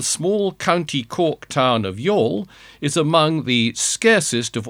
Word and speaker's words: Youghal: which is titled Y-O-U-G-H-A-L Basting Spoon. And how are you going to pronounce that? No, it's not the Irish provincial town of Youghal.